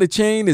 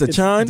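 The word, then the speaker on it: Achane: Is it, it's not a chain, it's a chan? – Devon Achan. – Achan. – Chan. Like Chan Crowder. – Like Achan. All right. – Devon Achan.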